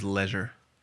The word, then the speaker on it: leisure: The word is leisure. – Why? – I think that's how the Brits say it, and it sounds classier.